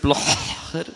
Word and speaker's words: Blocher: Der Vater von Ex-Bundesrat Christoph Blocher war Turnlehrer.